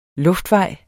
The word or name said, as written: luftvej